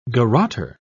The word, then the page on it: garroter: British English